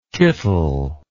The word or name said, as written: kittel